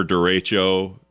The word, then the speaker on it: derecho: the first ever derecho, if I pronounce that right, derecho.